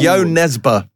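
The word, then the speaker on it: Jo Nesbø: And yes, I looked it up. That's how you pronounce it. It's Yo Nesbitt. – Yo Nesbitt, well done.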